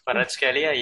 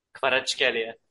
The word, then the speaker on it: Kvaratskhelia: Kvaratskhelia, yeah. – Wow, that was actually really impressive. How do you say it fast? – Kvaratskhelia.